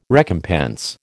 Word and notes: recompense: American English

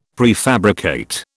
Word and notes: prefabricate: British English

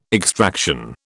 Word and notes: extraction: British English

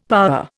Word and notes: power: Indian

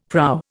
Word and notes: prow: Indian

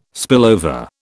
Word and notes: spillover: British English

